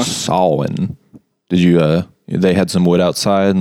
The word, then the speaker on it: Samhain: So it's actually pronounced, it's spelled Samhain, but it's pronounced Samhain. – Samhain.